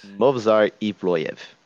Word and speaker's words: Movsar Evloev: Movsar Evloev. I'm not going to do an attempt at an Anik pronunciation of that one. – Movsar Evloev. – Yep, there it is. Perfect.